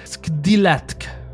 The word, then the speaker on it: Skdilatk: c'est un mot que j'ai beaucoup de misère à prononcer, mais Skdilatk. C'est S-K-D, déjà ça part mal, I, donc Skdi, la, L-A, T-K, Latk.